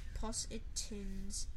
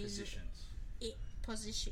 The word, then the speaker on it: positions: There are 15 Possetins in Regbi. There are 15 Possetins in... – Positions.